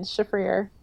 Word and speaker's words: Shafrir: Shafrir.